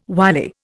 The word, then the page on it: wily: Indian